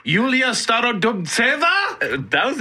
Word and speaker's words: Yulia Starodubtseva: Wait, you don't want to learn more about 55th ranked Yulia Starodubtseva? – That's pretty good. That's the pronunciation I heard.